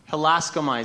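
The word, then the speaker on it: Halaskamai (ἱλάσκομαι): This be merciful, so in the language Luke wrote it, this is Greek, helaskomai. Halaskamai is how you say it.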